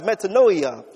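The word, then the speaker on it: metanoia (μετάνοια): Repentance, by the way, in the Greek is pronounced metanoia, and it means change of mind and change of attitude.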